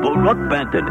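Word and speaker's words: Brook Benton: Brooke Benton is the way he pronounces it.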